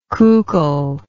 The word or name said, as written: coucal